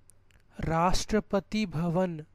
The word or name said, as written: Rashtrapati bhavan